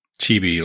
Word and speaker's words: Chibi: Is it Chibi? – Chibi. – C-H-I-B-I, like, whatever that is.